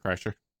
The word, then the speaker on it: Kreischer: Is that how you say his name? – Bert Kreischer. – Bert Kreischer, yeah.